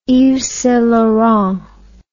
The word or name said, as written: yvessaintlaurent